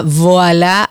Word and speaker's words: Voila: Entren a Voila, así mismo como se pronuncia, con B corta, Voila RD.